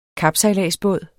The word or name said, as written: kapsejladsbåd